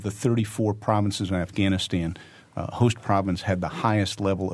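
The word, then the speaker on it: Khost: The Host Province, and we're pronouncing it in the Pashto-y, which is Host Province, but if you try to look it up, it begins with a K-H. So if you're trying to look it up, K-H-O-W-S-T.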